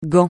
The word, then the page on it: Gand: francais